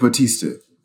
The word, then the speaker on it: Bautista: One of my favorite characters is Barista. Barista? How do you say his last name? Dave. Wait. Duke's character, David Barista. Barista? – Oh, Dave Bautista. – Bautista. Thank you.